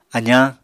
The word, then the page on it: azañ: apatani